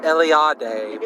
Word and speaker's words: Eliade: Eliade.